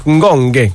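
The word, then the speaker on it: Ngonge: Si pronuncia così, va bene, hai ragione, perché non possiamo nominarlo sempre in due modi, così, Ngonghe, così mi hanno detto anche i colleghi di Verona.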